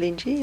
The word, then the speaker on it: Linji: Is that right pronunciation? – Mm-hmm. Linji, yeah.